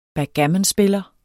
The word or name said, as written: backgammonspiller